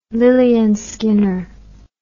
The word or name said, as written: lilleyandskinner